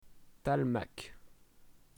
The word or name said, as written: talmak